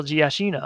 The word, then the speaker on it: Giacchino: Michael Giacchino.